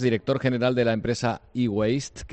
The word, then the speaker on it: eWaste: De hecho, mucha gente lo pronuncia diferente, lo pronuncia eWaste, sí. ¿No? Pero lo como has pronunciado tú es como sí. Es correctamente dicho.